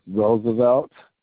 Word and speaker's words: Roosevelt: My name is Roosevelt. That's spelled R-O-O-S-E-V-E-L-T.